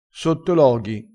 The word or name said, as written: sottologhi